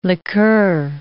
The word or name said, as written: liqueur